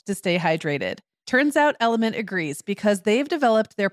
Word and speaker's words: LMNT: And when we come back, we're going to actually offer some Sarah, we're welcoming our sponsor, Element. That's spelled L-M-N-T,